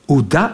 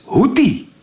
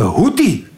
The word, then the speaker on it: Houthi: Het is niet hoe dat, het is Houthi. De Houthi.